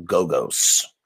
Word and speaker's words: Gogos: The Crimson Ghost, I've been calling him Basil Gogo. It's Basil Gogos.